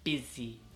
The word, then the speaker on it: busy: Pero la U se pronuncia como una I. Uh-huh. Entonces, busy, busy, ¿ok?